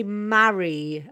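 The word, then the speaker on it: Mari: I know it's your lovely accent, but we say Mary a bit more. Mary.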